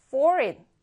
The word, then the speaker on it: foreign: foreign. 자, 이 G를 그로 발음하지 않고요. foreign. 자, 이렇게 발음합니다.